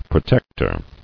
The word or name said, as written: Protectorship